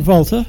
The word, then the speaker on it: Walther: And a good friend of Walther, am I pronouncing that correctly? – Walther, yes, Johann Gottfried Walther.